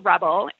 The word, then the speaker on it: RBL: So I have a company called Rebel. It's spelled RBL and it stands for relaxation based lifestyle.